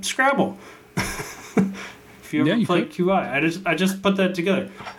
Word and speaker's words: Qi: For the longest time, I would say Qi because it's, I think it's literally Q. Is it QI? – It's QI, yeah.